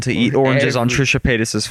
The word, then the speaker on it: Paytas: Paytas.